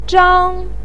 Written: zhang1

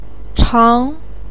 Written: chang2

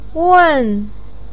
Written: wen4

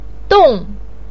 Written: dong4

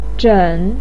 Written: zhen3

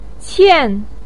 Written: qian4

